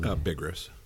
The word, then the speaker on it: Bigras: That's Bigras.